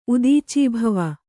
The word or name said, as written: ಉದೀಚೀಭವ